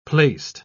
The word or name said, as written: placed